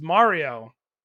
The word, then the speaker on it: Mario: So it's Mario, and Chris Pratt is Mario. Spelled Mario. He is Mario. He is not Mario.